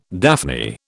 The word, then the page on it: daphne: British English